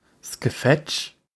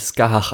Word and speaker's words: Scathach: Nein, wird natürlich Skahach ausgesprochen, aber okay. – Skahach. Da ist ein TH. Ich buchstabiere es mal, damit ihr alle mal ein bisschen in the loop sind. S-C-A-T-H-A-C-H. Alles ein Wort.